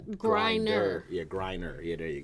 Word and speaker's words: Griner: Oh, yeah, Griner, like you grinding something.